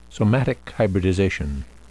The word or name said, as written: somatic hybridization